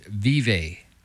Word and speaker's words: Vevay: It's Vive. I want to go to this one. – Okay. How do you spell it? – Vive, like it sounds. V-E-V-A-Y.